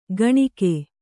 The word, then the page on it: ಕಾಣಿಕೆ: kāṇike